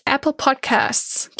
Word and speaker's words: Apple Podcasts: aber auch Apple Podcast, da Apple Podcasts. Ich kann es auf Deutsch nicht aussprechen, ich vergesse das S immer hinten. Das heißt Apple Podcasts, klingt ja sonst auch blöd.